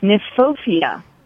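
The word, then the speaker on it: Kniphofia: It's often mispronounced, but it's Nymphophia with a K.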